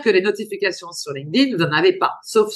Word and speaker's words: LinkedIn: Ah oui, oui, parce que l'intérêt de la newsletter, c'est quoi C'est que LinkedIn, et je suis obligée de bien prononcer LinkedIn parce que je suis avec toi, mais pas à la française.